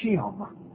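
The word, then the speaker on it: Shem (שם): It's pronounced as Shem.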